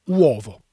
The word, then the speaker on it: uovo: Au singulier, donc on dit l'apostrophe uovo. – Mm-hmm. – U-O-V-O. Uovo, il faut tout prononcer, donc attention les voyelles, uovo.